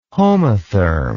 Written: homotherm

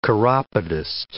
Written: chiropodist

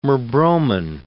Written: merbromin

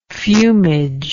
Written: fumage

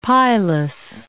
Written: pilus

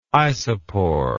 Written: isopor

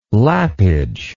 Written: lappage

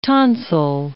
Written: tonsil

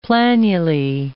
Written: planulae

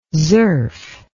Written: zurf

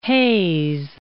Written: Hayes

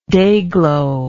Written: dayglo

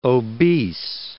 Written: obese

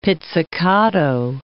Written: pizzicato

